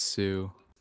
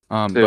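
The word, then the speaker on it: Hsu: however you pronounce her last name. – Yes, I think it's Sue. Sue.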